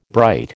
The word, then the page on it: bright: American English